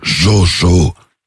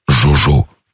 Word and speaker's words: Jojo: Ah non, c'est le jeu Lapin, c'est mon nom de famille, et Jojo, c'est mon prénom. – D'accord, ça s'écrit comment, Jojo, s'il vous plaît? – Non. Jojo, comme Josette, Jojo. Jojo, c'est mon prénom, et Lapin, c'est mon nom.